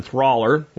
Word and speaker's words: thaler: The wide coin is meant to be equivalent of a German thaler, which rhymes with dollar, which is a reference to the Bohemian Valley where the major silver mines exist.